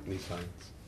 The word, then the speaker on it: nescience: I think is how it's pronounced, at least. That's how I've always pronounced it. Nescience. Yeah. Nescience. Yeah. Although it looks like Nescience. It's Nescience.